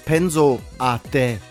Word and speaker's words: Penso a te: Wie spricht man das richtig aus? Penso a te.